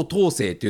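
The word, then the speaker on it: 桃青: なので、もともとはね、えー、松尾、青い桃と書いてね、松尾東生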